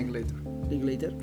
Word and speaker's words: Linklater: Linklater.